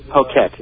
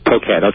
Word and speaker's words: Phuket: Phuket, it's spelled Phuket.